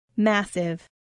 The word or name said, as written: massive